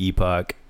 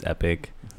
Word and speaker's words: Epoch: Oh, And then Epoch, apparently, I don't know, I just looked up the word and the pronunciation, and apparently it's Epic, like you said.